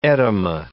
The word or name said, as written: etyma